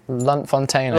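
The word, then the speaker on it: Lunt-Fontanne: and Sweeney Todd at the Lute Lute Fountain. How do you say it? Loot, Lute Fountain. Lunt Fontaine. – Lunt Fontaine.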